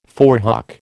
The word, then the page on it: forehock: American English